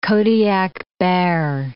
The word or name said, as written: Kodiak bear